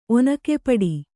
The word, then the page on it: ಒನಕೆಪೆಟ್ಟು: Kannada